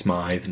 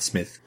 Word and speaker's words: Smyth: S-M-Y-T-H. It is pronounced Smythe, not Smith.